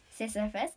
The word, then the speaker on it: Sisyphus: I can't say his name properly. – Sisyphus? – Yeah.